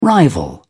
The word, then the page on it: rival: British English